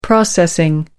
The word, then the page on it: processing: American English